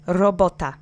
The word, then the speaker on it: robota: The accent is on the middle syllable, the penultimate syllable. Robota. Robota. Robota. Great. Try to roll that R if you can, but if you say robota, don't worry, you'll be understood. It's just you'll have an accent.